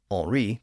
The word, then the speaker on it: Henri: The current monarch was Henry, or Henri, to use a more French pronunciation.